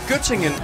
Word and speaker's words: Göttingen: Var det ved Gødringen? – Nej, Göttingen. – Göttingen eller ost. – Jamen, det er bare mig, der ikke kunne udtale det korrekt. – Ja, Göttingen, ja.